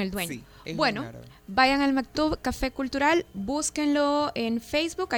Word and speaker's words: Maktub: se escribe M-A-K-T-U-B. Maktub.